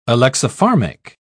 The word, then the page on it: alexipharmic: British English